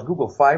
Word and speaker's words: Google Fi: En dat betekent, je moet het geloof ik denk ik uitspreken als Google Fi, want het gaat om financiële dienstverlening.